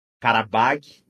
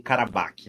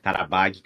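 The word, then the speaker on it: Qarabağ: Karabakh, não Karabakh, né? Karabakh seria a versão russa, né, do nome. Karabakh, que eu tô falando aqui, se escreve com Q-A-R-A-B-A-G, que seria a versão armênia, né? Armênia não, desculpa, azeri.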